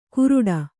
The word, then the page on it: ಕುರುಡ: kuruḍa